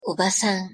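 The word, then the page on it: obasan: Japanese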